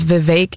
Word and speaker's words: Vivek: And actually, his first name is Vivek. It rhymes with cake.